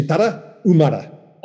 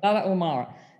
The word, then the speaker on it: Tarahumara: And so I say Tarahumara. Tell me how to say it again, really. – Yeah, just don't pronounce the H. You say Tarahumara. – Tarahumara.